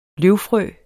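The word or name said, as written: løvfrø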